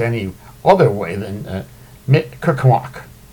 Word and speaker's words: Mi'kmaq: no charges in Proud Boys incident at Mi'kmaq ceremony.